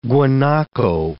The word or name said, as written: guanaco